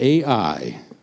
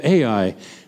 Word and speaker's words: Ai: The next step, we're getting ready for the battle of AI. Somebody tell me how you say that one. Yeah, that's good. Just like it's spelled. Okay, they're getting ready for the battle of AI.